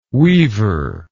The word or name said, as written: weever